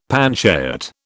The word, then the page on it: panchayat: British English